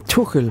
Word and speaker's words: Tuchel: Så vil jeg bare sige, lad du mærke til, nu har vi lært det, hvordan navnet på landstræneren i England skal udtales. Thomas Tuchel. – Tuchel. – Ja, for han er jo tysk.